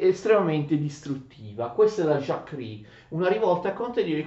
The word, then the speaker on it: Jacquerie: Attenzione, questa rivolta dei contadini questa avviene nel maggio-giugno 1358, viene chiamata Jacquerie, attenzione, Jacquerie, ma si legge Jacquerie in francese.